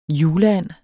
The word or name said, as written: juleand